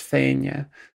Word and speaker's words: Fianna: In Scots Gaelic, it's pronounced Fainia.